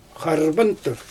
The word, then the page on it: xarrbantur: Xyzyl